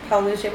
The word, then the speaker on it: Paolucci: Paolucci.